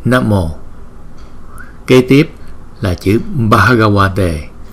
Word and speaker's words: Bhagavate: Bhagavate, Bhagavate, Bhagavate. Chữ V, chữ V này nè, chúng ta đọc là wa, wa. Giống như chữ W vậy đó.